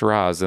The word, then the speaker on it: Ra's: al Ghul, pronounced Ra's in the movie,